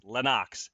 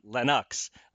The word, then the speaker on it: Lennox: Lennox Lewis clarified that his name is pronounced Lennox, not Lennox, as he's let Americans apparently pronounce it wrong his whole career, his whole life.